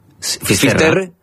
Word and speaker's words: Finisterre: Finisterre.